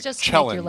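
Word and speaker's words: Kjelland: Right, Jelland, like a cello.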